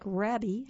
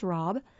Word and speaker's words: rabe: And it's pronounced, it looks like rabbi, R-A-B-E, or rabe, but it's pronounced rob.